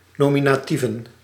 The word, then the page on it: nominatieven: no·mi·na·tie·ven